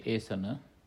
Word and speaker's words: esana: The first word is asana, A with the diacritic mark hyphen on top, so that's asana, pronounced longer, asana, E-S-A-N-A, long A, asana. Second word is gavesana. That is G-A-V-E, long E, S-A-N-A, Gavesana.